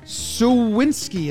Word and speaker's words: Suwinski: Suwinski, is that how you say it? Yep.